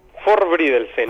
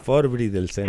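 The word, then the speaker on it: Forbrydelsen: Y no me sorprende que de aquellas latitudes estés recomendándonos una serie entonces que se llama Frolvidensen o algo así. – Forbridelsen. – Ah, Forbridelsen, sí, muy bien.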